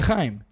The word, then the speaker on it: Haim: es Haim, no más tranquilo. – No es Haim. – Es Se pronuncia Haim.